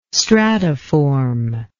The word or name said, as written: stratiform